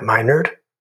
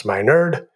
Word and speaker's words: Minard: I'm your host, Dr. Matt Meinerd, pronounced Meinerd, and I am honored to be your host.